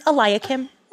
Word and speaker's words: Eliakim: Actually, the way you pronounce it is Eliakim.